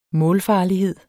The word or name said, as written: målfarlighed